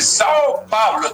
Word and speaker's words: São Paulo: Dice São Paulo, ¿está bien dicho así? – Perfecto.